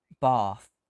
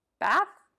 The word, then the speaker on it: Bath: Do you pronounce it Bath? – Yeah, but that's a good question. Do people from the South pronounce it Bath? – They do, because I called it Bath and my friend from London corrected me. A very strong correction. – Oh. You mean Bath, don't you? – Yeah.